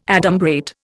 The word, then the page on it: adumbrate: Indian